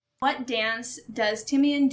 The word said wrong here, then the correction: Timon: do to distract the hyenas? Timon. – Timon.